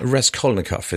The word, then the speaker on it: Raskolnikov: R- Raskolnikov? Raskolnikov is how I've heard it pronounced.